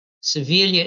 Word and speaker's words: Siviglia: Siviglia is spelled S-I-V-I-G-L-I-A.